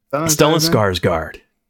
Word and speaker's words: Stellan Skarsgård: Stellan Skarsgård.